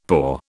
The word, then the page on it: pour: British English